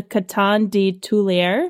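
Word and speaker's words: Coton de Tulear: It's mostly French. So here it goes. I practiced this. It is called the Coton de Tulier.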